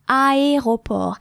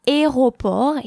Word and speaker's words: aéroport: I often hear students mispronouncing the word, the French word for airport, which is aéroport. I often hear aéroport here for the same reason the A and the E cannot in French be combined to form a different sound,